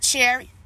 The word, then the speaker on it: Cherry: My name is spelled Nichelle, excuse me, Cherry C H E R R Y.